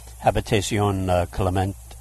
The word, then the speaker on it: Habitation Clément: First of all, you did mention the Habitation Clément.